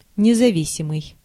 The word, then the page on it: независимый: /nʲɪzɐˈvʲisʲɪmɨj/